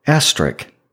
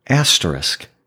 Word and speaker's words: asterisk: For many years, I didn't realize that I was pronouncing this word incorrectly. Most people call it asterisk, but it's actually asterisk.